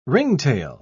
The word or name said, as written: ringtail